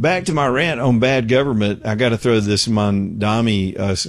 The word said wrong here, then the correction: Mamdani: I keep saying his name wrong. Is it Mamdani or Mondani? Dami. Anyway, Mamdani.